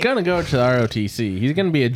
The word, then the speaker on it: ROTC: They don't call it that though. They call it ROTC. – That's true. – Yeah, it rhymes with Nazi.